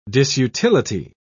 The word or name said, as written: disutility